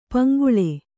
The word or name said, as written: ಪಂಗೋಳಿ